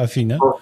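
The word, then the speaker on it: Afina: Okej, okay, to po Imuseku założyłeś Afinę, to się tak się czyta, tak się wymawia, Afinę? – Tak czytam i tak wymawiam.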